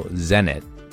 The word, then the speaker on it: Zenit: And the Zenit, no, Zenit, rhymes with Send It, is an intermediate shoe that can point you towards harder, steeper boulders and sport climbs.